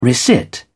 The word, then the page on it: resit: British English